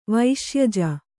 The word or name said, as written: ವೈಶ್ಯಜ